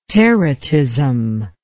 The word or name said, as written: teratism